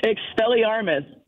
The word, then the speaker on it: Expelliarmus: Expelliarmus.